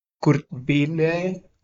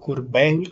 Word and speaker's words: Kurt Vile: Cerramos este tributo a The Velvet Underground con otro artista, Kurt Vile o Kurt Vile, como quieran pronunciarlo, haciendo el tema Run, Run, Run.